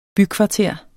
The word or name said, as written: bykvarter